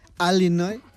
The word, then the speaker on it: Illinois: Sabes que hay, como aquí en España, hay acentos diferentes en Estados Unidos y los nativos del estado de Illinois lo pronuncian Illinois. – Algo que a mí me enseñaron cuando me saqué un título oficial de este idioma es hablar fonéticamente para que no haya ninguna duda. Y si uno busca ese nombre fonéticamente, es Illinois.